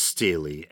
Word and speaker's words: stele: And I think it's pronounced Stele, S-T-E-L-E, usually like a stone with an engraving in it, that may make mention of the House of David.